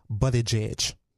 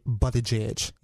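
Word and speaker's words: Buttigieg: I just got on YouTube, and he said he pronounces it Buddy Jidge. That's how he pronounces his name. – Buddy Jig? – Yeah, Buddy Jidge.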